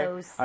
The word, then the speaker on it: photo: 그리고 참고로 ph는 f와 성질이 비슷하기 때문에 발음할 때 포토가 아니고요. f. photo 이렇게 부드럽게 해주셔야 돼요.